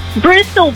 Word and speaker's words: Bristol: You didn't say it right. – Bristol, baby.